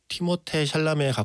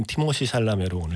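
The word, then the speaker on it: Timothée Chalamet: 원래 발음은 티모테 샬라메에 가깝다고 하는데 우리나라에서 이미 티모시로 부르기 시작했으므로 언젠가는 바로 잡히겠지만 아직까지는 티모시 샬라메로 오늘 칭하도록 하겠습니다.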